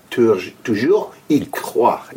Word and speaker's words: Toujours y croire: Toujours y croire. – Toujours y croire.